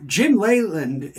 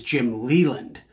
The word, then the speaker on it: Leyland: Well, the correct way, as I understand it, is Jim Leyland. But the Midwestern pronunciation is Jim Leland. So when he became the Pirates manager in 1986, I believe, Leyland became Leland.